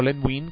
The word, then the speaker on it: Len Wein: Los escritores eran John Ostrander, Len Wein, o Len Wein, como nos dijo Herb Trimpey, que se debe de pronunciar, John Byrne, y entintado por Carl Kessel.